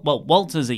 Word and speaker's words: Walter: Well, Walter's easy because in Austrian, in Austria, you sort of pronounce W's as V's.